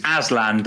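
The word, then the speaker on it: Azlan: 2017, you won a gold medal at the Sultan Asian Shah Cup. Have I said that right? – I think it's Aslan, but Aslan. We're not far off.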